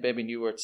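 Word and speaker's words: Bebe Neuwirth: I can never pronounce it. It's Baby Newirth or Newirth. – Baby Newirth, yeah.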